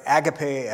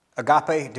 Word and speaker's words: agape: agape or agape, depending on how you pronounce it.